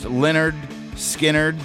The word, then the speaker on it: Lynyrd Skynyrd: Lynyrd Skynyrd drops their debut album, pronounced Lynyrd Skynyrd, giving us this song, Simple Man and Freebird.